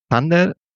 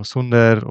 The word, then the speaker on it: Zunder: Sander, angelehnt, vielleicht was von vornherein richtig ausgesprochen, lehnt sich an das englische Wort Thunder tatsächlich an, daher auch der Blitz in unserem Logo, ist aber auch in Spanien nicht immer glasklar, also Sunder oder Zunder ist durchaus eben auch geläufig und ja, eine Frage, die ich regelmäßig bekomme.